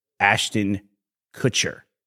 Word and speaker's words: Ashton Kutcher: You know, over the years, I've gotten so much grief about how I say the name Ashton Kutcher. How do you say it? – Ashton Kutcher. – Okay. I think in the past I've said Ashton Kutcher.